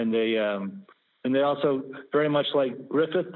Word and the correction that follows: Gryffin: Did I say that right? – Gryffin, G R, I think it's G R Y N.